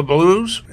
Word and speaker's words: Blooze: And the track you just heard was, well, let me spell it, B-L-O-O-Z-E. As Chuck Berry would pronounce it, blues.